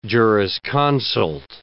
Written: jurisconsult

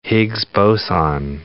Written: Higgs boson

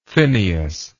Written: phineas

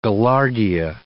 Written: gaillardia

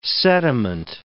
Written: sediment

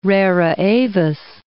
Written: rara avis

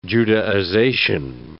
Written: Judaization

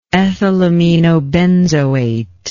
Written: ethylaminobenzoate

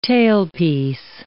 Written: tailpiece

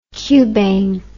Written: cubane

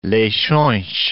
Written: Leixoes

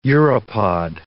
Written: uropod

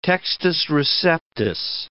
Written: textus receptus

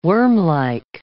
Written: wormlike

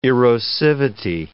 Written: erosivity

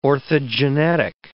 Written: orthogenetic

